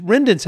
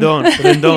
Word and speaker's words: Rendon: Rendon's how you guys say your last name, is that right? – Yes, yeah. For people who don't speak Spanish. – Aha! – Spanish-speaking, Rendon. – Rendon.